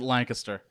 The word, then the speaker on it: Lancaster: We pronounce it Lancaster. You got to say it like you're sneezing.